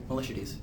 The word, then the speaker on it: Miltiades: Miltiades. – Miltiades, isn't it? – Miltiades? – It's Miltiades. – Miltiades. – Both of you added extra syllables.